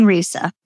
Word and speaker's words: Risa: Rise. Ekdahl. Did I say that correctly, Dawn? – There we go. – You did. Well, Dawn Risa. Close enough. Yes.